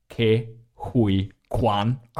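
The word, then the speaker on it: Ke Huy Quan: And in particular, because can we give a round of applause to my guy, Data Ke-Huy Quan.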